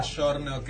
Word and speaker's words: giorno: Ponerle la palabra giorno, que es día, en realidad se escribe giorno, pero el sonido de la G es como sh, o de la C a veces, ponele, a ver, chita, que es ciudad, se escribe cita, pero es chita.